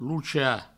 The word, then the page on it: lochar: provençal; loucha